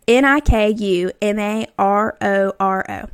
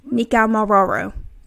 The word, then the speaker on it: Nikumaroro: N I K U M A R O R O. Nikumaroro. – Nikumaroro. – I think that is how you say it.